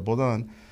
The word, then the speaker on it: Baudin: Se escribe en castellano, sería Baudín, se pronuncia Bodán. Eh, o yo lo pronuncio Baudin en mi francés, ¿verdad?